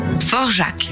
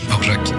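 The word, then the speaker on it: Dvořák: Dvorak. Je le prononce pas bien. Forjac. Forjac.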